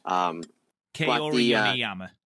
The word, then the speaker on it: Kaori Yoneyama: Kaori Yoneyama.